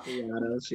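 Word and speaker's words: Kayiatos: I do not want to butcher his last name. Kayatos? – Kayatos, yeah.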